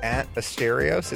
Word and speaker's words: Asterios: Well, I'm a comedian, and you can follow me at Asterios. It's spelled A-S-T-E-R-I-O-S on Twitter.